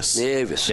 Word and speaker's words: Nieves: They brought Alex Nieves. Is that how you say it? – Nieves. Yep, he scored his first goal the other night on Tuesday.